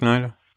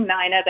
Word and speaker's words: Nina: It is Nina, which is spelled N-I-N-A, Yoshida, Y-O-S-H-I-D-A, Nelson, N-E-L-S-E-N, .com. Did I get that correct, Nina? – You got that correct. I've got one name, Nina, that everyone pronounces Nina, and one name, Nelson, that everyone spells N-E-L-S-O-N, but, you know, my husband decided to spell it N-E-L-S-E-N, and sometimes it's hard to find me.